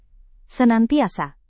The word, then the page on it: senantiasa: Indonesian